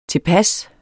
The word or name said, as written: tilpasse